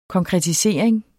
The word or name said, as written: konkretisering